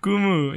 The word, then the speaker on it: Kumu: Kumu, which rhymes with... Mumu. Kumu.